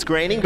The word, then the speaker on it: Groening: It's Groening, but I'll give it to you.